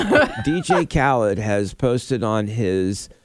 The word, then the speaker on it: Khaled: And then now this latest thing with Drake and DJ Khalid. Did I say that right? I think so. – Probably Khaled. – It might be Khaled. DJ uh, well, Khaled.